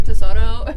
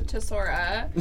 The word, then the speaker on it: Tesora: Okay, wait, so you know how everybody at Phil's, when they come up, sometimes they're like, oh, can I get the Tesoro? And it's like, no, it's a Tesora.